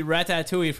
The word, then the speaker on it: ratatouille: And then we had the ratatouille from the movie Ratatouille. Mm-hmm. – Yeah. – That was good. – I believe that's exactly how you pronounce it, too.